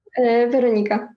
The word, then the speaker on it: Weronika: Veronica.